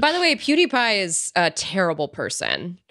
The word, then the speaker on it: PewDiePie: Felix PewDiePie, never know how to pronounce that guy's name, basically said that he was PewDiePie. – PewDiePie, thank you very much.